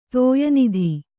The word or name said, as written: ತೋಯನಿಧಿ